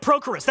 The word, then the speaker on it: Prochorus: Prochorus.